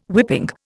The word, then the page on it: whipping: Indian